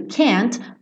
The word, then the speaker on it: can't: Porque vocês já devem saber, ou se não sabem, saberão agora, que esse T do can't muitas vezes não é pronunciado.